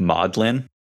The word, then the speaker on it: Magdalene: And then she makes a connection between the word Magdalene and the English word, how it's usually pronounced, more like maudlin.